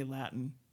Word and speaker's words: Latin: I just got all these angry emails about not pronouncing that T, that I had to show Latin its respect. – Well, you and I come from a part of the U.S. where those T's, you cannot pronounce them before an N. You have to say Latin. You can't say Latin. I can't even do it.